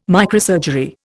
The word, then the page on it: microsurgery: Indian